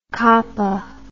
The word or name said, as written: cappa